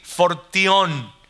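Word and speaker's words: fortión (φορτίον): Y cuando habla de responsabilidad se refiere a otra palabra en griego que se, que se dice o que se pronuncia más o menos así, fortión, fortión.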